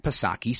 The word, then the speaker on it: Psaki: Speaking to reporters at the White House on Friday, Jen Psaki, she acknowledged, and you know her, Jen Pacircle Back Psaki, silent P.